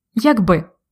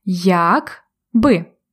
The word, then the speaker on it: якби: Англійською if, якби. Але також українською можна вживати ці два слова окремо як би. Ось послухайте. Якби разом якби окремо, тобто відрізняється наголос якби разом, якби окремо.